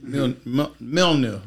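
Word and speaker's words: Mjölnir: How do you say Thor's hammer? Is it the Mjolnir? Or however you say it? – Mjolnir. It's a weird way to say it.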